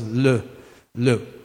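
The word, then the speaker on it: l'eau: It's actually from a French word, water, which is L-E-A-U, L apostrophe E-A-U, which is pronounced as le, le.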